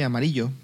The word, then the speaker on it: Amarillo: Entre Groom y Amarillo, se escribe amarillo, pero lo pronuncian Amarillo, que estamos prácticamente terminando ya el tramo de hoy incluso.